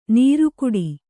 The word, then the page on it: ನೀರ್ಕುಡಿ: Kannada